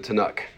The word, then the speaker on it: Tanakh: Jewish people call it the Tanakh. Did I say that right? The Tanakh. Okay, so this is a an acronym formed from the first letters of the Torah.